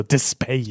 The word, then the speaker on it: Despayre: Okay, so really quick, that's spelled Despayre. So Despair.